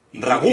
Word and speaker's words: ragú: Vale, el ragu Ah, que, que lleva acento la U. Sí, lleva tilde. Ragú.